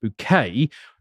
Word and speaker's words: Bucket: One of the daughters of this family, Hyacinth Bucket, pronounced Bouquet, is aspirational and wishes to go upwards.